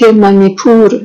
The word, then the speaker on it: مانیپور: ایشون در ایالت مانیپور زاده شدند. مانیپور که تلفظ صحیحش از دو کلمه مانی و پور درست شده. مانی یعنی جواهر و پور یعنی سرزمین، یعنی سرزمین جواهر.